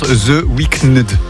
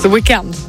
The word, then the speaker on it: The Weeknd: Moi, je suis aveuglé par la lumière, là, de, ça se prononce The Weeknd. – The Weeknd. – The Weeknd. – Il manque un E dans le... – Ouais, ils ont oublié un E dans l'écriture de leur nom, donc je les taquine.